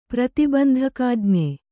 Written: ಪ್ರತಿಬಂಧಕಾಜ್ಞೆ

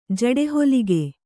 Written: ಜಡೆಹೊಲಿಗೆ

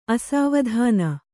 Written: ಅಸಾವಧಾನ

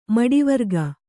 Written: ಮಡಿವರ್ಗ

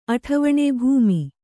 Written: ಅಠವಣೆ

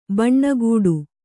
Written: ಭಾನಗಡಿ

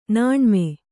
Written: ನಾಣ್ಮೆ

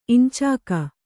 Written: ಇಂಚಾಕ

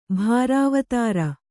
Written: ಭಾರಾವತಾರ

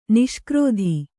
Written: ನಿಷ್ಕ್ರೋಧಿ

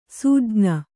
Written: ಸೂಜ್ಞ

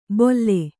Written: ಬೊಲ್ಲೆ

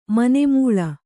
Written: ಮನೆಮೂಳಿ

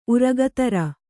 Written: ಉರಗಧರ

